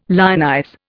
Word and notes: lionize: Indian

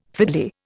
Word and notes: fiddly: Indian

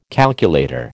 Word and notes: calculator: American English

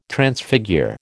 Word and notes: Transfigure: American English

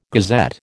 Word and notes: gazette: American English

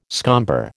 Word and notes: scomber: American English

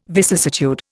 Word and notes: vicissitude: Indian

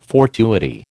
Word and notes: fortuity: American English

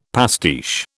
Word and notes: pastiche: British English

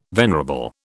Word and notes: venerable: British English

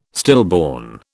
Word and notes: stillborn: British English

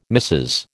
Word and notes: Mrs: American English

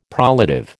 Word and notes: prolative: American English

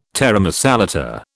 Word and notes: taramasalata: British English

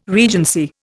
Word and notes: regency: Indian

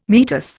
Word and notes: meatus: Indian

